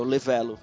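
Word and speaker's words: Le Vélo: Tem o Le, Le, Levelo, não sei como é que fala isso. – É o Levelo.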